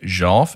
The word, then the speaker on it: Jolf: And if you're hearing that smooth, buttery pronunciation, like I thought it was called golf. No. This is Jolf. Because it's JJJ. JJJ. – Uh-huh. – OLF. Because of John, Justin, and Jordan.